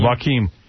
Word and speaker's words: Joaquin: Is that how you say it? Joaquin. – Yeah, Joaquin.